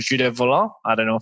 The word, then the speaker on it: Volant: Volant. – Volant.